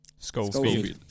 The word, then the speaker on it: Schofield: uh, hur, hur uttalar man Schofield eller Shofield? – Schofield.